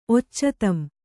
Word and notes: ಒಚ್ಚತಂ: occatam

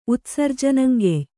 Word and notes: ಉತ್ಸರ್ಜನಂಗೆಯ್: Kannada

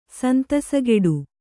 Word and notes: ಸಂತಸಗೆಡು: santasageḍu

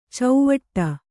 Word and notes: ಚೌವಟ್ಟ: cauvaṭṭa

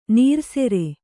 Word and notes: ನೀರೆಸರು: nīresaru

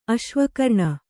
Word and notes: ಅಶ್ವಕರ್ಣ: aśvakarṇa

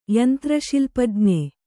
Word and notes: ಯಂತ್ರಶಿಲ್ಪಜ್ಞ: Kannada

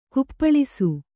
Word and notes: ಕುಪ್ಪಳಿಸು: kuppaḷisu; Kannada

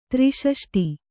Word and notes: ತ್ರೈಷಷ್ಟಿ: trai ṣaṣṭi

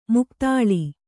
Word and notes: ಮೈಗತ್ತಲೆ: maigattale

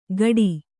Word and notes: ಗಡಿ: Kannada

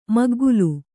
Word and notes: ಮುಗ್ಗಲು: kannada

